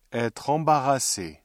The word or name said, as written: être embarrassé